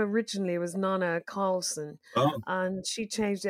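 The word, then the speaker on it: Neneh: Although I think it's probably, I don't think it is of your genre, the singer Nina Cherry. Is 57 today, And did she make that song Buffalo something? – Yeah, Buffalo Stance, yeah. She's actually Nana Cherry. Most people mispronounce her name.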